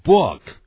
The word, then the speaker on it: book: 到时候想起书也是一样的发音， book， B-O-O-K， book，这个 book，我现在会念，但也不要念成呢 book，更不要念成 book。